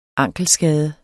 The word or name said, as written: ankelskade